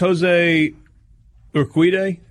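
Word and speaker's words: José Urquidy: Winners, Jose Urquidy. Is that how you say his name? The game four starter for the Nationals. I'm sorry, for the Astros. It was a bullpen day. – You asked me for pronunciation? – Yes. – Okay. Yeah, that sounds right.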